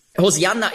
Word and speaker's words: Hosianna: Hosianna ist eben die griechische Aussprache von Hebräisch Hosianna. Das kommt daher, im Griechischen gibt es keinen Sch-Laut.